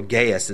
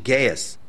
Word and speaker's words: Gaius: He goes on, except Crispus and Gaius, or Gaius is actually the pronunciation, Gaius, lest anyone should say that I baptized in my own name.